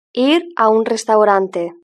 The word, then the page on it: ir a un restaurante: European Spanish